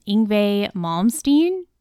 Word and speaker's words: Yngwie Malmsteen: Dad would be happy to know that I have finally listened to, I'm going to butcher this name, Yngwie Malmsteen.